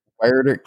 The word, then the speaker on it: Huerter: Huerter, Kevin Huerter.